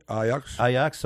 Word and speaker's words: Ajax: You played for Ajax, right? Is that what it's how to pronounce it? – Ajax, sorry.